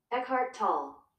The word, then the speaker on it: Eckhart Tolle: Eckhart Tolle.